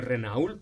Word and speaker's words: Renault: Renault, se escribe Renault, pero se dice Renault.